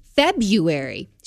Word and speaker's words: February: And I'm like, yeah, but you pronounced it February. She goes, well, that's how it's pronounced, but it's not how it's spelled.